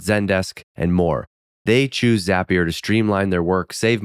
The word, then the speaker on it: Zapier: That's the reason why Zapier rhymes with happier. Bet you didn't know that. Now you know how to say it the right way, too.